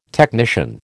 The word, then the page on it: technician: American English